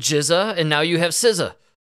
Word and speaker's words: SZA: It is SZA.